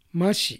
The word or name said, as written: Mashi